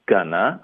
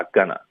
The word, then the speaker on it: gonna: 嗯，这个开头我们有一个 I'm，我们会弱读成 not 的 t 呢，我们要省略 I'm not gonna，后面 going to 就发 gonna， I'm not gonna，这四个音节会说的比较快， I'm not gonna。